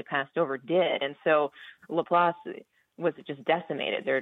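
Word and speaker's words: LaPlace: Yeah, it's pronounced La Place.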